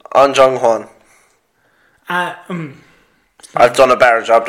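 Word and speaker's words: Ahn Jung-hwan: A-H-N. – What are you doing? No, that's. – An Jung Hwan is how you say it.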